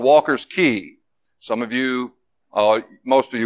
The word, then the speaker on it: Cay: Key is spelled C-A-Y.